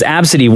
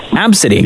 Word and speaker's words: ABCDE: So we just got two tweets at Kiss925, two tweets who said the exact same thing as you, that ABCDE is pronounced Absidy. One person, Dana, in her tweet, she said, my friend, a doctor, has seen that name and said it's pronounced Absidy.